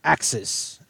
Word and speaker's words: Uxas: This is Axis, which is the pronunciation, and then the actual spelling of it is kind of like Bologna and Bologna. Axis is spelled UXAS,